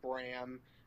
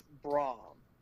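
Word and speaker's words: Braun: I'm kind of disappointed that TNA never pulled the trigger on a heel faction with him, Braum, and you will never ever hear me say Bram, except for this one time, since that's how TNA and Impact Wrestling likes to pronounce his name. Fuck all y'all, it's Braum.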